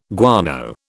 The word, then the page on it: guano: British English